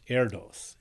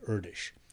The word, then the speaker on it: Erdős: Oh yes, it looks like it ought to be pronounced Erdős, and I for a long time said mistakenly Erdős, because it's leaving out the so-called diacritic marks, you know, little dots and dashes and check marks and stuff over the letters. The name is spelled E-R-D-O-S. – Yes. – Paul, but it apparently it's pronounced something more like Erdős.